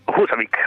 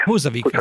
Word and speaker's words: Húsavík: Husavik. – Husavik, certo, ok.